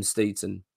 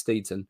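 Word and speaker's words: Steidten: But what it does do it puts an enormous amounts of pressure on Tim Steeton. That's his name, by the way. Apparently, that's how you pronounce it. That's what I hear. Unless I'm wrong, let me know. But I think it's Steeton.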